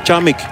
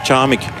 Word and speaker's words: Charmig: Ik ga eventjes, want ik heb dat al langer willen doen, vragen wat de juiste uitspraak is van die Deen, Charmig. What is the right pronunciation of from Anton Charmig? – Charmig. – Charmig. Oké, thank you very much.